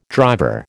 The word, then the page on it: driver: American English